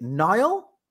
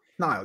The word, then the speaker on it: Niall: Is it Nile N I A L L? Nile. Yeah, there we go.